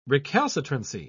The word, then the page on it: recalcitrancy: British English